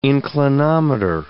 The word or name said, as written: inclinometer